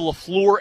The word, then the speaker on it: LaFleur: I said Matt LaFleur, right? – You say, I like how you say it. – LaFleur? – LaFleur. – Oh, okay. – You like, you go, floor.